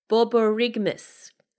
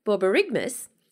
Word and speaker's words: Borborygmus: Now, I actually researched how you pronounce this before this podcast, but of course all that information has left my brain. So I'm going to give it a go anyway. Borborygmus. Borborygmus. That's B-O-R-B-O-R. Y-G-M-U-S, Borborygmus.